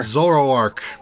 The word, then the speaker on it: Zoroark: You'll also get a code that will be sent to you that can be redeemed for a Hisuian Zoroark. I hope I said that right. – Hisuian sure. Zoroark.